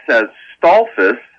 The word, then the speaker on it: Stolfus: Yes, Stolfus, S-T-O-L-F-U-S,